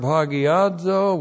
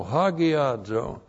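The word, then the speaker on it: hagiazo (ἁγιάζω): To sanctify is the Greek verb hagiazo, which looks like this. In the Greek, H-A-G-I-A-Z, long O, hagiazo.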